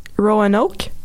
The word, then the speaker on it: Roanoke: Roanoke. – Puis là, ah, j'ai été affreusement déçue. Ah, c'est parce que, OK, mm. c'est... Comment ça se prononce? Roanoke. OK, c'est bon ça.